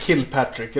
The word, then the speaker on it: Kilpatrick: man, man uttalar det Kilpatrick, eller hur? Ja, det vill jag göra i alla fall.